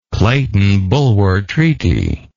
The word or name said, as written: clayton bulwer treaty